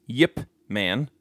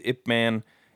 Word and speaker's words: Ip Man: So Josh says that it is pronounced Yip Man, right? Okay. And then Vito jumped in and says that it is pronounced Ip or also known as Yip. He says, I believe it in Mandarin, it is Ip Man, and in Cantonese, it is Yip Man.